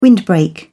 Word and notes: windbreak: British English

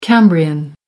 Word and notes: cambrian: American English

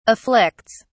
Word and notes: afflicts: English; British English

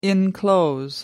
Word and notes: enclose: American English

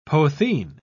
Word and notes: potheen: British English

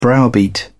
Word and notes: browbeat: British English

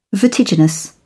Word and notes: vertiginous: British English

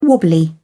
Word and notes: wobbly: British English

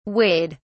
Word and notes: weighed: British English

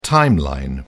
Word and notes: timeline: British English

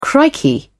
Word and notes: crikey: British English

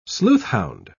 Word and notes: sleuthhound: British English

